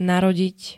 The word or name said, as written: narodiť